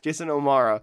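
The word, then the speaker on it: Jason O'Mara: So as a Jason Omada, I'm like, that's not how you say it. Okay, I'm sorry. Jason Omara as Jeffrey Mace.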